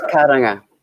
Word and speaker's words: Kurunga: It's pronounced Kurunga. It's a Māori name. It's a Māori term.